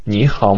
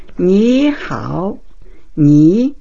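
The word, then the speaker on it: Ni hao (你好): Ni hao, ni, N-I, e hao, H-A-O, ni hao.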